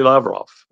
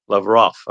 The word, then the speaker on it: Lavrov: Especially Lavrov, who would see him, Lavrov, I should say, I keep saying his name wrong.